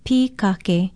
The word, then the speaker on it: pikake: Our Hawaiian word of the day is pikake. Pikake is spelled P-I-K-A-K-E, and don't forget the kahako on the first syllable, pikake.